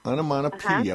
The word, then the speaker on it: onomatopoeia: How do you pronounce that? – Onomatopoeia.